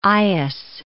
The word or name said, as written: eyas